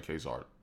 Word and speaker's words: Ka-Zar: No, you were saying Ka-Zar.